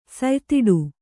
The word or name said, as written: ಸಯ್ತಿಡು